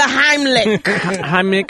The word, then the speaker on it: Heimlich: No, the Heimlich.